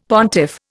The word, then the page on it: pontiff: Indian